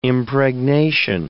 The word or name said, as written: impregnation